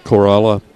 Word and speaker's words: Corolla: Tuckasegee, Corolla.